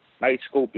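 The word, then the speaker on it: Knightscope: Nightscope is spelled K-N-I-G-H-T. Scope, S-C-O-P-E. And I've actually seen these.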